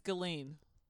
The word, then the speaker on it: Ghislaine: I think it's Ghislaine. – I'm pretty sure it's Ghislaine. – Trying to explain this woman's name. It's like saying island. You don't pronounce the S.